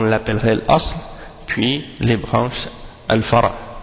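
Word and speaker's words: al-fara (فرع): Par opposition c'est al-fara. Le pluriel de fara c'est furu'. Al-fara ça s'écrit fa-ra-ayn. Fa-ra-ayn.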